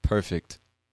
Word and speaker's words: perfect: I say the T. Perfect. It's just very, very, very soft. And you don't, to be honest with you, you don't have to pronounce that T because many people don't when they're speaking fast. But just naturally, perfect. There's a soft T at the end there.